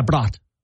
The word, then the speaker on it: Braut: B-R-A-U-T. Brat? – B-R-A-U-T. B-R-A-U-T. – Is there a Brat? Yeah, Brat.